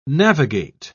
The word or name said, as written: navigate